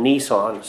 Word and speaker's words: Nisan: On the 10th day of the month of Abib, later the month called Nisan, spelled a little differently, but pretty much pronounced the same as that car that you maybe see around.